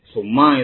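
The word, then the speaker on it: Summa (ثُمَّ): Wa qauluhu wa summa li tartibi wa tarahi. Summa bi dhammil muthallathati. Summa dengan di dhammah huruf sa titik tiga. Ini untuk mendobet ya. Mendobet cara membacanya.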